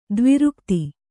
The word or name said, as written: ದ್ವಿರುಕ್ತಿ